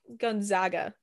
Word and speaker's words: Gonzaga: Let's talk Gonzaga.